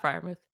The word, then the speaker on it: Freiermuth: Is it Freiermuth? – Yeah, Pat Freiermuth.